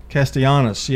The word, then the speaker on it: Castellanos: Castellanos? – Castellanos, yeah, yeah.